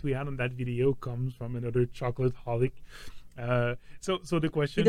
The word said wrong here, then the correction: chocoholic: He doesn't even know how to say it right. Chocoholic.